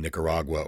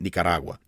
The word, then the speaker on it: Nicaragua: you would inevitably pronounce it Nicaragua. And of course, now that Nicaragua is the proper pronunciation in Spanish.